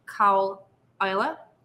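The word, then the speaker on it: Caol Ila: We'll go on to Cow Isla, and it is pronounced Cowl Isla. Even though I said that Isla, it's Isla. – Really? I've always said Isla, but it's Isla, huh? – Well, because it's spelled differently than Isla. – Yeah. – Right? There's no Y.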